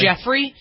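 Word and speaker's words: Giuffre: Is it Guffrey? Is that how she pronounced her name? I think it's Jeffrey. – Jeffrey? – I think it's how it's, yeah, how it's pronounced is Jeffrey, yeah.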